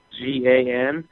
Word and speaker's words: McGuigan: McGuigan is spelled McGuigan,